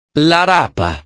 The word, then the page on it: la rapa: italiano